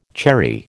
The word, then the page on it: cherry: American English